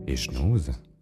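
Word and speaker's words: Echenoz: Lei ha scritto Jean Eschnaus, Eschnaus. – Sì, nella mia famiglia pronunciamo la Z.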